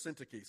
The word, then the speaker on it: Syntyche: We called Dr. Horn, who's Greek and Hebrew professor up at North Greenville, and said, "Hey, how do we pronounce this? Because I think it's like Syntyche or something." And he said it's Syntyche. So I know it's right.